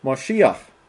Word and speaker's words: Mashiach: The word translated Messiah here is Mashiach, pronounced Mashiach.